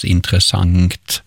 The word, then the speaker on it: intressant: Det här uttalet då som Nanna nämner, intressant, det finns också belagt i man kan slå upp i SOB och se att det här har kunnat uttalas intressant med ett ng på slutet.